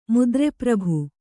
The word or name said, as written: ಮುದ್ರೆಪ್ರಭು